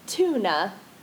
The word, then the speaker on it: Tone: It's not spelled Tuna, but apparently it's pronounced Tuna. – How is it spelled? – T-O-N-E.